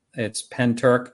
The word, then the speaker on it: Pentyrch: It's spelled Pentyrch, but it's Pentyrch.